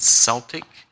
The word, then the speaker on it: Celtic: were practiced among the Celtic. It's also, you can also pronounce it Celtic. It's C-E-L-T-I-C people group,